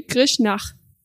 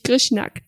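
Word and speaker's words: Grishnákh: Und KH wird dann ausgesprochen wie CH, also wie Ch. Und darum ist es zum Beispiel Grishnach und nicht Grishnak.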